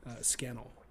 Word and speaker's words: Scannell: I'll also give a thanks to Sam Scandron Scannell, whose name I also mispronounced on last week's pod. So again, a nice, easy association, Scannell like flannel.